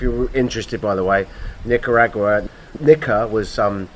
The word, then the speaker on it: Nicaragua: I'm in fucking Nicaragua, right? I pronounced that incorrectly coming into America, and the... – No, you pronounced it correctly, but they're idiots.